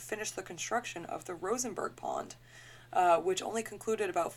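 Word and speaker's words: Rožmberk: The Czech spelling of it is R-O-Z-M-B-E-R-K. So Rosenberg is phonetically how you would pronounce that.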